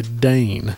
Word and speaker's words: Dane: And a person that has no magical powers is considered a Dane. That's how I pronounce it anyway, D-A-N-E.